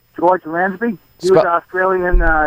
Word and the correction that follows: Lazenby: Lansby. – George Lansby. Oh, Lazenby. Lazenby. – Yeah. Yeah, Lazenby.